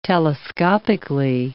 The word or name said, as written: telescopically